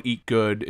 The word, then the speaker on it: Eet Gud: It's this place in Trenton called Eat Good. It's been there forever. It's spelled E-E-T-G-U-D.